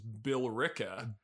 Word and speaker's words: Billerica: This is another gem of a town name pronounced Billerica.